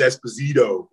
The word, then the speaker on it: Esposito: But to pronounce it correctly, people were trained by Phil Esposito, the hockey player back in our day. – Right. – And that's how we pronounced it when you were in America, New York or in Boston. So it was Esposito.